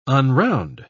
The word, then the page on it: unround: British English